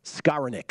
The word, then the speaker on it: Skowronek: Matt Grissom says, Skowronek. That's how you pronounce the Rams wide receiver's last name.